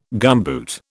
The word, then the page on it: gumboot: British English